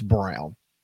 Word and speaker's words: Braun: You shouldn't just be able to spell your name Braun and then say it's Brown. I mean, that is outrageous. – Did I mispronounce it? – Yeah, you said Christian Braun because that's exactly the way it's spelled. But it is pronounced.